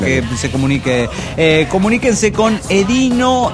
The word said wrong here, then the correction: Edinho: Bueno, Ediño se pronunciaría. Es E-D-I-N-H-O.